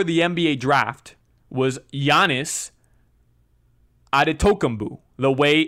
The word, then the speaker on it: Antetokounmpo: Now, it's spelled Antetokounmpo.